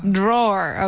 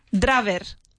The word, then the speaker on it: drawer: Drawer. – Okay, I really don't like the word drawer. It's spelled D-R-A-W-E-R. Draver. Drawer. And we say drawer.